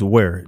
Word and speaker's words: Ware: Um, how do you pronounce your last name? Um, it's Ware.